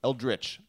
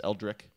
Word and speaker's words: Eldritch: Eldritch. I've been told that I say it wrong as Eldrick.